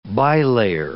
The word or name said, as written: bilayer